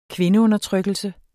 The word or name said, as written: kvindeundertrykkelse